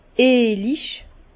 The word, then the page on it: eelig: Bas-Rhin; Herrlisheim